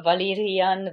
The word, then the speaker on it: Valyria: Let me dive in on the most confusing debate of all, the Valyrian Velaryon one. So the country, the place is Valyria. The house is Velaryon.